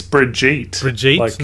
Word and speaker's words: Brigitte: And Brigitte Nielsen, if I'm pronouncing her name correctly, It's Brigitte.